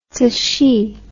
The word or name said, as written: cixi